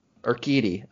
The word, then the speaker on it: Urquidy: Urquidy.